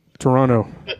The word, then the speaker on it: Toronto: Toronto. They don't pronounce the T. – Oh. – The second T.